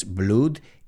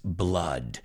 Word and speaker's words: blood: No es blood, it's blood. B-L-O-O-D.